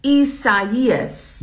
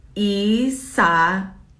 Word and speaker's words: Isaias: Isaias. – It sounds like a Nelly song. – Pretty much, yeah. – Isaias. – Yes. Got it. Thanks. Isaias.